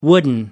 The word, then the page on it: wooden: American English